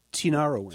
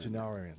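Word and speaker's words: Tinariwen: How do you pronounce it? Correctly pronounce her name? – Tinarowin. – Tinarowin. I always mispronounce that.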